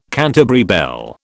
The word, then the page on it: Canterbury bell: British English